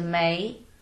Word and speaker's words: May: And then I changed into May